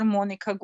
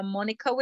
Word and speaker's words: Monika: So if you Google my name, Dr. Monika Gostic, you will find me.